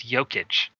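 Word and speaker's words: Jokić: Is it a soft J or a hard J? Is it like yogging or jogging? – Well, I don't think I don't think people have figured out yet, but I think it's Jokic.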